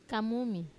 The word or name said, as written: kamumi